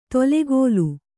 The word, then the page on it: ತೊಲೆಗೋಲು: tolegōlu